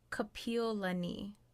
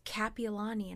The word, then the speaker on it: Kapiolani: For me, besides, like, Hawaii, it's also Kapiolani. People say Kapiolani, which is a name / street name over here. – Right. – Just everyone says Kapiolani, and I'm like, Kapiolani.